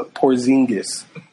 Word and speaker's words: Porzingis: Wait, did I say it wrong? – He said Przinski. – What is it? Old man, so Porzingis.